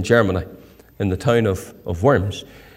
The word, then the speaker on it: Worms: And then it was printed in Worms, as it's pronounced by the Germans.